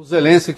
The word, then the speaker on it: Zelensky: O Zelensky, que eu pronuncio Zelensky, porque é como eles pronunciam lá e como tem pronunciado a imprensa americana, tá? No mundo, na verdade, né? Que é Zelensky. Então eu falo Zelensky.